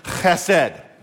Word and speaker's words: chesed: There's a guttural in Hebrew where you pronounce the, CH with a lot of phlegm, like you're hawking up a loogie as you say the word chesed.